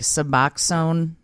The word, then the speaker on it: Suboxone: Now, 6 years later, I have to take Suboxone.